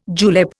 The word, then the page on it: julep: Indian